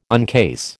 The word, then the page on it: uncase: American English